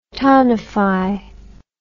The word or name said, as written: townify